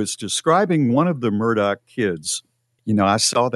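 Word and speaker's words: Murdaugh: There's a lot of people mispronouncing it, but it is Murdoch.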